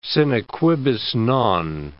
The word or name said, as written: sine quibus non